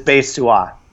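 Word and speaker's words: Bae Suah: That's actually, the only thing that's different is it's Swa, like it's two syllables, but it's Bae Swa.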